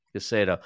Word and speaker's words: Guseinov: Guseinov.